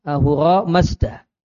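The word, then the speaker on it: Ahura Mazda: Masdar belakangnya. Ahwara masdar. – Ronnya enggak kebaca. – Ahura. Ahura masdar.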